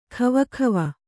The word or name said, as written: ಖವಖವ